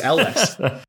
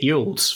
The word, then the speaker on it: Elwes: So The Princess Bride is an actor that I have, since our discussions on him a few weeks ago, I have Googled the pronunciation of his surname. That is Cary Elwes. So really? Is that how it's actually said? – Elwes, yeah, it's Elwes. I've been pronouncing it Yules for the vast majority of my life.